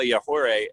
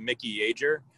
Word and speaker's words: Yajure: we figured we don't know how to say this. So we just kept calling him Mickey Yager, knowing that that was definitely not the pronunciation.